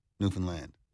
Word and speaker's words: Newfoundland: The locals back home, we always the emphasis on land. So it, I always say the easiest way to remember it is understand Newfoundland. – Understand Newfoundland. – It rhymes with understand. Exact same emphasis, same everything.